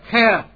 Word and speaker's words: Heth: It's the letter Heth, or some pronounce it Het.